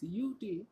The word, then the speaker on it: UT: It's UT. – U as in U? – Yeah, sort of.